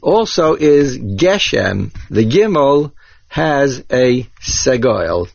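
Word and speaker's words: Geshem (גשם): Rav Yaakov writes that the word is pronounced Geshem with 2 segoilim,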